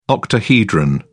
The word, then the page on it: octahedron: British English